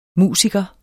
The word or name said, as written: musiker